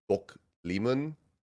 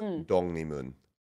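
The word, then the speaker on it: Dongnimun (독립문): Like Bokleemun, which is just an area, is written as like Bokleemun. But it's pronounced Dongnimun, even though it's written with like a G sound and an L.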